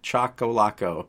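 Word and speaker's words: Choccolocco: Yes, you got the pronunciation correct, Chocolaco.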